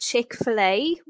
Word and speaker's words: Chick-fil-A: I think Americans pronounce it Chick-fil-A, would I be right? – You've nailed it, and I'm not surprised you've nailed it.